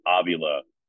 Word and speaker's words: Avila: And I went up to him before the 2013 ALCS, and I said, Alex, I'm going to pronounce your name Avila during this series. And he said, I'm good with that. That's how it's pronounced. And then I had Tiger fans the entire time because they're used to hearing the PA announcer say, now batting, Alex Avila. They're less used to the TV announcer saying, now batting, Alex Avila. But his name's Avila.